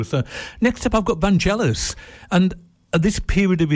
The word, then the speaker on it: Vangelis: Vangelis, it should be, with a hard G.